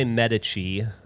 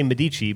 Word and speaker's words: Medici: We were at Cafe Medici. – That's how you say it almost. That's fine. – No, in Austin, people say Medici, but... I was assaulted by every Italian person in this country.